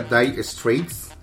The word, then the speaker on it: Dire Straits: Se pronuncia Die Streets.